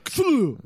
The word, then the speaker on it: Cthulhu: It's supposed to be a two syllable word. – Cthulhu.